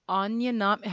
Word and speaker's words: Anya Nami: Anya Nami. How do you spell that? – Anya, like Anya Taylor-Joy, A-N-Y-A, and then Nami, N-A-M-I, like the One Piece character.